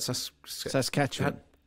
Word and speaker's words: Saskatchewan: How do you say that? – Saskatchewan.